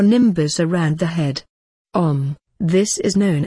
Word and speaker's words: Om: The pronunciation is OM.